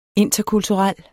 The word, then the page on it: interkulturel: [ ˈentʌ- ]; [ ˈenˀtʌ- ]